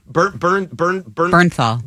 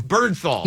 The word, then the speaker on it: Bernthal: Bernthal. – Bernthal.